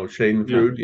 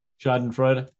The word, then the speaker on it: Schadenfreude: It could be they're reading it because of, and I'm going to butcher this phrase or this word, Schadenfreude. – Oh, Schadenfreude, yeah. – Schadenfreude.